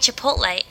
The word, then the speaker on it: Chipotle: Is it Chipotle? Am I saying that right? – Chipotle. – He says Chipotle. I say Chipotle.